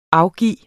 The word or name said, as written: afgive